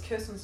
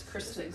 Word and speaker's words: Kristen: I've always thought it was Kirsten Stewart, but it's Kristen Stewart. – It's always Kristen. – On the Kristen. – Twilight podcast, they're like Kristen.